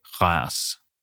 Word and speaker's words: Reims: But in French, the best that I can pronounce it, it's Douay Reims.